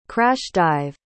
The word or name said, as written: crash-dive